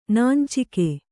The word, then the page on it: ನಾಂಚಿಕೆ: nāncike